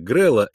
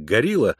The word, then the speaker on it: Грелле: Грель, чье имя писалось как Грель, но произносилось как Грелла, иногда как Горилла,